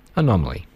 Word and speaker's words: Anna Molly: But it's pretty much in this song pronounced Anomaly.